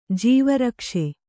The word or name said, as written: ಜೀವರಕ್ಷೆ